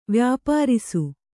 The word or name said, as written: ವ್ಯಾಪಾರಿಸು